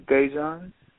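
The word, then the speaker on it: Dajon: My name is Dejan. – Dejan. Can you spell that for me, please? – Yes, it's D-A-J-O-N.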